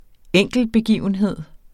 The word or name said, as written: enkeltbegivenhed